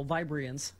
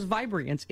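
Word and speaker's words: Vibriance: 19 minutes after the hour, this portion of the show brought to you by my very, very not natural Vibrians. See how I put the extra syllable in there? – Yes. – And the wrong emphasis? – Right. – Because Vibrians, it is the best face product I've ever used.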